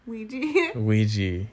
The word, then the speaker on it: Ouija: How do you say it? Ouija? – Ouija.